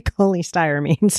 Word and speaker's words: cholestyramine: And I keep saying colostramine, but it's actually cholestyramine.